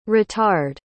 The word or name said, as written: ritard